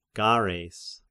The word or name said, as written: Gareis